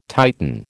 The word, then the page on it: Titan: American English